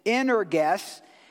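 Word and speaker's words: energus (ἐνεργής): Actually, the pronunciation is a little different than that. It's energus.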